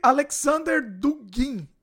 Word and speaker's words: Alexander Dugin: Em cima disso também, Putin pode ter sido influenciado por um tal Alexandre Alexander Dugin, é assim que pronuncia, que mistura coisas como pautas conservadoras anti-LGBT a pautas populares e sociais, tipo uma mistura de direita conservadora e esquerda social.